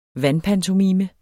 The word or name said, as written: vandpantomime